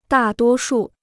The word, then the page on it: 大多数: dà duō shù